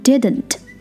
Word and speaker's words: didn't: 好，除了上面这些举到的例子之外，这个句型还有个发音的特点，就是一个省音 NT 和 ND 组合的时候都可以只发一个 N 的音，所以这边 didn't 直接变成 didn't.